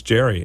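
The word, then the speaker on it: Gerry: Gary Dugan is my guest, and I'm really excited to talk to him about it's Jerry. It's spelled like Gary, but it's Jerry.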